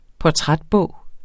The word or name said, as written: portrætbog